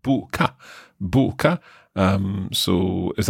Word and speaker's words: boka: B-O-K-A. – Right. Okay. Boka. So it's that oo sound for the single O that sometimes confuses me.